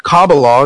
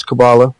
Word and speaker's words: Kabbalah: I've been doing paranormal research and getting involved in the Kabbalah, is how you say it in Hebrew, but in English it's Kabbalah.